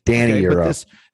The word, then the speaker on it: Daniil Yurov: Daniil Yurov. – I think it's Danny. – Okay. – Danny Yurov.